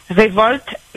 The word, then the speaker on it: Révolte: I-C-O-U-T-E. – I-C-O-U-T-E, oui. – Trait d'union. – Oui. – Révolte, R-E-V-O-L-T-E.